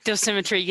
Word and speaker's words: dosimetry: What's the word? Dosimetry? – Dosimetry, yes. – Dosimetry.